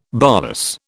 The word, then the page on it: bolus: British English